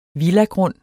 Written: villagrund

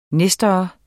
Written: nestor

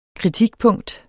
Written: kritikpunkt

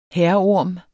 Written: hærorm

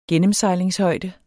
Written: gennemsejlingshøjde

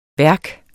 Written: værk

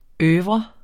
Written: oeuvre

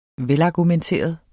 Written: velargumenteret

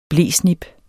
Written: blesnip

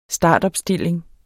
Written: startopstilling

